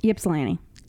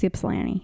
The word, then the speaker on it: Ypsilanti: How do you pronounce that? – Ypsilanti. – Ypsilanti. – That's Ypsilanti, yep.